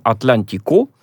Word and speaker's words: Атлантико: Ну, в этом плане интересна статья, э, французский такой ресурс, очень его рекомендую нашим читателям, «Атлантико», да, ударение на последний слог, вы его можете почитать на нашем сайте и на СМИ.